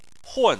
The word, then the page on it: hun4: chinese